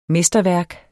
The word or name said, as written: mesterværk